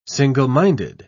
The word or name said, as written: single-minded